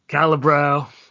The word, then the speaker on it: Calabro: Thomas Calabro. Am I saying it right, or is it Calabro? – Calibro. Yeah. Calibro, man.